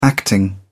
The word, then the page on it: acting: British English